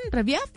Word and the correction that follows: Revieve: Revive, Reviv.